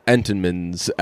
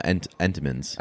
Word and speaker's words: Entenmann's: I'm like, yo, where's the Entenmann's at? No one ever pronounces that second N in Entenmann's, huh? – No, Entenmann's.